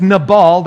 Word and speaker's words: Nabal: And then a new guy that we're meeting named Nabal, or I might accidentally pronounce his name Nabal, but it's Nabal, that's the way you would pronounce it.